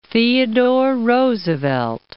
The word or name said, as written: Theodore Roosevelt National Park